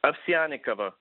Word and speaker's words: Ovsyannikova: Ovsyannikova.